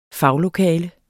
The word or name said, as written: faglokale